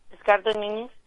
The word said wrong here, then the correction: Edgardo Núñez: Ay, qué bonito lo pronuncia, ¿eh? Edgardo Núñez. Es Edgardo Núñez, mija, pero te lo vamos a valer porque sí te entendimos como quiera, ¿eh?